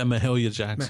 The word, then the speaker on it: Mahalia Jackson: Yeah, Mahalia Jackson.